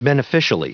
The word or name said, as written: beneficially